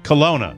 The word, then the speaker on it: Kalona: That's just south of Kelowna. Is that how you pronounce it? Kelowna. – Yes. – How do you spell it? – K-A-L-O-N-A. – Oh, see, it was typed C-O-L-O-N-N-A.